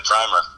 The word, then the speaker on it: primer: Also, I wrote the book, and I was calling it Hollywood Primer. Apparently, it's pronounced primer, which I found out after it came out.